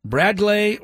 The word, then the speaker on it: Bradgley: Right-handed reliever, 21-year-old Bradley Rodriguez. B-R-A-D-G-L-E-Y, pronounced Bradley, according to Baseball Reference anyway.